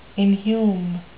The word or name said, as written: inhume